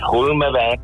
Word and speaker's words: Hulmenweg: Habe ich es jetzt richtig ausgesprochen? – Richtig, Hulmenweg, das kommt vom Hulmen, der höchste Punkt von Winterthur.